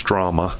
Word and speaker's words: Drama: And it's spelled like it sounds, Drama.